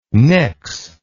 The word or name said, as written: pnyx